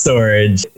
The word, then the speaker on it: Storj: Is it pronounced storage or is it storage? I really I've been looking at it because it's got that J at the end. It's pronounced storage.